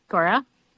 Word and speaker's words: Guara: G-U-A-R-A. Say that right? – Yeah, I think that's right, Gora. That's how I say it.